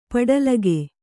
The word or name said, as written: ಪಡಲಗೆ